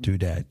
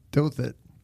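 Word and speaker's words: Douthat: I pronounce it Dudat. You pronounce it Dothit.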